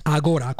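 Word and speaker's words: Agorà: Agora, con l'accento sulla A, sulla Va bene,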